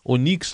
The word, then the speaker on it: Onyx: O Onyx que vai ser aí Onix Lorenzoni, né? Que ele, ele disse que a pronúncia é essa.